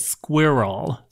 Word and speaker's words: squirrel: So Tan France, who's from England, says that us Americans pronounce it all wrong, and it's not squirrel. He says it's two syllables, mm-hmm. So we should pronounce it squirrel.